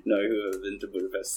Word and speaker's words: Budapest: and V says that it's pronounced Budapest, not Budapest.